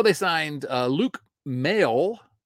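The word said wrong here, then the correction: Maile: And actually, I presume that's how you pronounce that. Now I'm looking at it. It has to be. – Myla. – Luke Myla.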